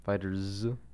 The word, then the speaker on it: FighterZ: I don't know how it's pronounced. Maybe it's just Fighters. – That's what my friend tells me. He says it's just Fighters. – Fighters.